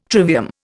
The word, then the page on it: trivium: Indian